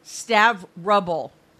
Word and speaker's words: Stavropol: Is it Stavrobel? – Stavrobel. R-A-B-L-E. Okay. It's S-T-A-V-R-O-P-O-L.